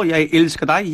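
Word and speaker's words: jeg elsker dig: Jest trochę twardawo, jaj ilskadaj, jaj, czyli pisze się J-E-G, ale mówi się jaj ilskadaj, D-I-G, czyli ty, D-I-G, ale jednak daj, czyli G na końcu jest zawsze taki J.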